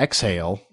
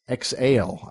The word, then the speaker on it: Exaile: and it's it's it's spelled E X A I L E. Um, so it's not exhale like breathing out, but it's exhale.